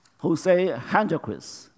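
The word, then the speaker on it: José Henríquez: Jose Hendriquez, I mean, that's how you pronounce his name.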